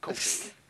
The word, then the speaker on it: Kofi: Well, we're going to get on coffee in a minute. – No, wait. How you say it? – Coffee. – Kofi? – Kofi. – Oh, excuse me. Kofi. – There we go.